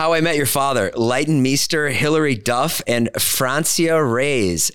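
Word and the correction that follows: Francia Raisa: Am I saying that right? Francia Reyes? – Francia Reza.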